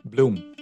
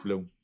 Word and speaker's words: Bloom: Bloom. – Bloom, like the flower. Okay, that was the stutter. Maurice Bloom. Maurice Bloom. – Bloom.